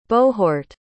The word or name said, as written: bohort